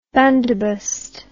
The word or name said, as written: bandobust